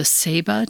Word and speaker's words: ceiba: Central America and South America, the ceiba tree. Am I pronouncing that correctly? – Yes.